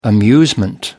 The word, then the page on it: amusement: American English